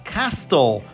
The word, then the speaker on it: castle: Por exemplo, castelo em inglês, que se escreve C-A-S-T-L-E. Vocês querem, vocês têm uma vontade louca de falar castle. Parece bonito, parece lindo, mas não. Vocês vão tirar a letra T e vai virar castle.